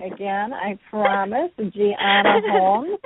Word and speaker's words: Gianna: Gianna, if you're in Italy, it's Gianna, uh-huh.